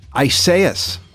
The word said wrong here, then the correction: Isaias: I think. – Nope. – Well, what? Tell me. – It's got a more of a kind of an accent to it. Isaias.